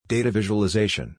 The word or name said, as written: Data Visualization